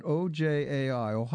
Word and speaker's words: Ojai: In 1999, she founded the how would you pronounce that? OJAI. Ojai. Uh-huh. Digital Art Center in Ojai, California.